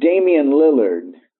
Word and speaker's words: Damian Lillard: We have Damian Lillard. Am I correct? – Yes, that's how you pronounce it.